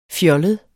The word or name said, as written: fjollet